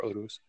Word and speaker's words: Urus: Yeah, the detractors of the Lamborghini Urus or Urus or however you want to pronounce it. I prefer Urus.